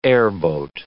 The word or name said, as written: airboat